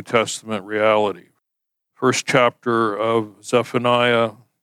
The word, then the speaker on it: Zephaniah: Our Old Testament passage lesson today comes from the book of Zephaniah, or I guess the proper pronunciation is Zephaniah. Listen to a video by a Jewish scholar. That's how he pronounced it.